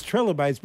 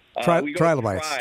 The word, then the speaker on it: trilobite: Trilobites, I always thought of it as trilobites, but it's trilobites is how it's pronounced? – Trilobites.